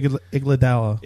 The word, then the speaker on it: Iguodala: Iguodala? Yeah. How do you say it? – Andre Iguodala.